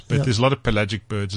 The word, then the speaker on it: pelagic: So the albatross that never comes ashore is known as a pelagic.